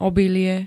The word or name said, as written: obilie